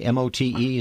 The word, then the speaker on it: Mote: Uh, the is it Mote Marine Laboratory, M O T E? Is that how it's pronounced? That's correct. Mote Marine out of Sarasota.